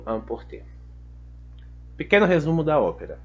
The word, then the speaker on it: emporter: O verbo amporter, de fato, a pronúncia é emporter.